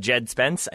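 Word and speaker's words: Djed Spence: was Jed Spence. I think that's how you say his name. It's DJED. But they were not able.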